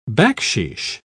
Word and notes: backsheesh: British English

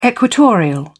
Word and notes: equatorial: British English

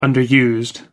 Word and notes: underused: American English

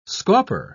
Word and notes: scauper: British English

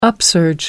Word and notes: upsurge: American English

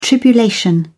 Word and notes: tribulation: British English

